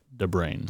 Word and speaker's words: de Bruyn: You know, so it's D-E and then B-R-U-Y-N. – Right. – Pronounced the brain.